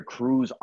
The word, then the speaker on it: Cruz: So if you want to look me up on YouTube, it's under Cruz Henri.